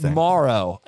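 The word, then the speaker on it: Moreau: I saw an interview with his mom, who basically said, "We pronounce it Morrow, but most people pronounce it Morrow." – Well, then it's Morrow. Morrow. I mean, just because everyone else is wrong, his mom said it's Morrow.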